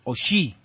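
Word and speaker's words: O'Shea: El capitán de Irlanda es el señor Dara Oshí, o Oshea se escribe.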